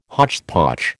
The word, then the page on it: hotchpotch: American English